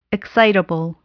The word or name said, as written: excitable